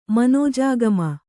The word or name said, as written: ಮನೋಜಾಗಮ